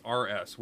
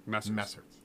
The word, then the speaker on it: Messrs: To Messrs, and that's Messrs, which is an old British phrase addressing a group of men. – Mm-hmm. Okay. – Yeah, Messrs. That's how it's pronounced. – Like Mr. Misters.